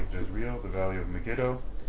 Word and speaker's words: Megiddo: In Israel, they pronounce that Megiddo. – Right, Megiddo.